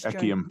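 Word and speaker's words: Echium: Okay, Mike in Bantry has something, I think, is it pronounced ecumen? It got burnt. – Ecium, probably. – Ecium.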